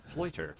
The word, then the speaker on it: Floiter: Floiter. – Floiter. – Like loiter, but with an F.